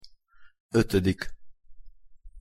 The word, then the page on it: otodik: Hungarian